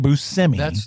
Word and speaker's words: Buscemi: And Steve Buscemi, that's how you say it, Buscemi.